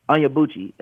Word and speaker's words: Onyebuchi: How do I pronounce his last name? I think it's Chochi Onyebuchi. Onyebuchi is how you pronounce his last name.